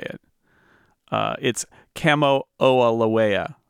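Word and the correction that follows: Kamo'oalewa: No, Kamo'oalawa. Kamo'oalawa. Hawaiian is actually very easy to pronounce, but you just have to count all the vowels and pronounce them all.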